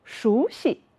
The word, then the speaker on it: 熟悉: 大家看，西这个字本音应该念成一声西，在这个词语里面我们要读成轻声，熟悉。